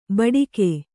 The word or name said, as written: ಭಾಡಿಗೆ